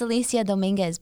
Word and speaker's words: Alyssia Dominguez: It's Alyssia Dominguez, but people, I've gotten like Alyssia Dominguez and all these goofy ones.